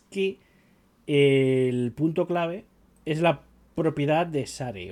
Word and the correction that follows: share: o de Shar. Share. Share, share, eso, cómo se pronuncia.